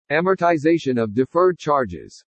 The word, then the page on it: AMORTIZATION OF DEFERRED CHARGES: english